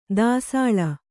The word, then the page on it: ದಾಸಳ: Kannada